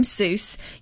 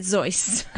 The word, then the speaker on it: Seuss: And he said, You're wrong as the juice and you shouldn't rejoice. If you're calling him Seuss, he pronounces it Zeus, which I thought was really fun.